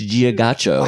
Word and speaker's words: Giacchino: You know the the composer that I'll add my asterisk to of like they're doing good work is the Michael Giacchino. – Michael Giacchino. Yeah. – I don't think that's how you pronounce it, Matt. I think it's Giacchino.